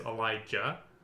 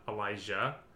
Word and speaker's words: Elijah: the specific way to pronounce my name, like the correct way, is Elijah, not Elijah.